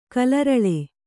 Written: ಕಾಲರಳಿ

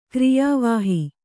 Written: ಕಾರ್ಯವಾಹಿ

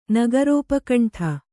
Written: ನಗರೋಪಕಂಠ

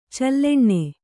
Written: ಚಲ್ಲೆಣ್ಣೆ

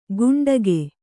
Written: ಗುಂಡಗೆ

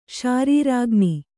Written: ಶಾರೀರಾಗ್ನಿ

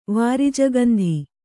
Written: ವಾರಿಜಗಂಧಿ

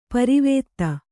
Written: ಪಾರಾವತ

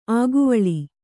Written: ಅಕ್ಕಿವುಲ್ಲು

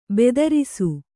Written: ಭದ್ರಿಸು

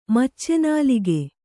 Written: ಮಚ್ಚೆನಾಲಿಗೆ

